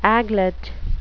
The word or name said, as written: aglet